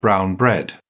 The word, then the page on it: brown bread: British English